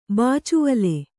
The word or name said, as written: ಬಾಚುವಲೆ